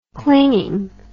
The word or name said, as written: clingingness